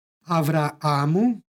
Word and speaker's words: Avraamu: U prethodnoj pak reči istoga stiha Avraamu izgovorili smo sa dva a, jer po Bibliji ime i tako glasi Avraam.